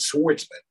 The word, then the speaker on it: swordsman: Which you think would be the actual pronunciation would be swordsman, because there's a W in there for a reason.